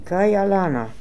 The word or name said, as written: Kaialana